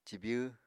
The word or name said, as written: cibyw